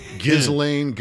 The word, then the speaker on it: Ghislaine: How do you say it? Ghislaine Maxwell? How do you say that first name? – Ghislaine.